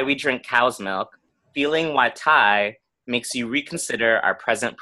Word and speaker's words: wytai: Okay, so it's pronounced Y-tai, W-Y-T-A-I.